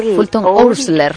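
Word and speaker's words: Oursler: Y estaba como, bueno, decía un escritor que se llama Fulton Owls, Dios mío, es que es para pronunciarlo, Fulton Ousler.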